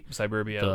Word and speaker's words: Cyberbia: Cyberbia, which is spelled like cyber. – Oh, okay. – And then the Rooftop Combat, which is Cyberbia, also like suburbia, but like cyber, yeah, but cyber, cyber suburbs. Yeah.